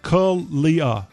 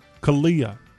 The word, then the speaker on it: CALIA: C L I A is pronounced Kalia, Kalia.